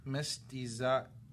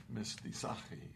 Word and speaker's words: mestizaje: But is there like a mestiaje or not sure. – Mestizaje. Right there. – Mestizaje.